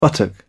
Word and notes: buttock: British English

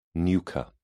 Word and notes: nucha: British English

